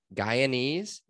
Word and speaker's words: Guyanese: His mother is, oh man, how do I say this? Guyanese, is that right? – Yeah, that sounds about right.